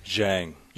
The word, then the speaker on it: Zhang: Zhang. – Zhang. Dylan Zhang. You know, spelled like you would never think that you would say that. Zhang.